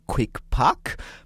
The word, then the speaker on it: QuickPack: Sie legt großen Wert darauf, dass wir das auf Deutsch aussprechen, QuickPack.